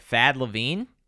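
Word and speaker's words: Thad Levine: All right, so one more on the list is, and I want to make sure I say this right, Thad LaVine.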